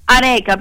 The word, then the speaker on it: areca: Dice, le piante d'interno sono palma areca, penso che si pronunci così, sì.